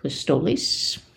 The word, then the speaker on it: pistoles: Pistoles? – It looks like pistols. – I know, but I think it's pronounced pistoles. But, yeah.